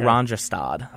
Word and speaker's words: Oranjestad: Oranjestad. – Oranjestad.